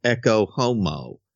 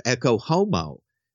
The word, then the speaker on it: Ecce Homo: We've left behind beautiful artwork like The Last Supper, and now we have Ecce Homo. Which just rhymes with Globo Homo, you know, in so many ways, which is fitting.